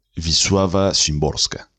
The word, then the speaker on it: Wisława Szymborska: Así que la pronunciación es eh, como una V labiodental, digamos, como lo entenderíamos en español. Y sería algo como Visuava Szymborska.